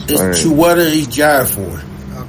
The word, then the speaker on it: Chiwetel Ejiofor: It's Chiwetel Ejiofor.